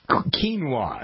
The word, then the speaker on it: quinoa: It's National, I don't know if I pronounce it, quinoa. That's how you pronounce it, quinoa.